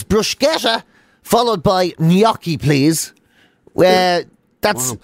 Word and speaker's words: gnocchi: Apparently, you're supposed to put a Y in it. So apparently, it's like gnocchi.